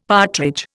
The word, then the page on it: partridge: Indian